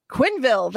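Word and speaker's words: Quenneville: Quinville. That's how you say it. Yeah, it's an I, not an E.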